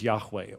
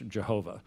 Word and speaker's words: Yahweh: that name is Yahweh, or as we slaughter it in English, Jehovah. That could not have been its proper pronunciation, but Yahweh is probably the best pronunciation.